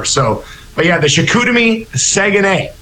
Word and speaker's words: Saguenay: I said it wrong there, which is kind of funny. It's Saguenay. The Chicoutimi Saguenay.